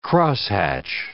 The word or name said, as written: crosshatch